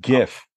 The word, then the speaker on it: Jif: I like Jif. You know, is it Jif peanut butter? – It's pronounced GIF.